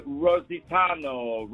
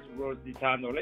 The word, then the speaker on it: Rositano: His name is Robert Rositano. Robert Rositano. Let me spell it for you. It's R-O-S-I-T-A-N-O.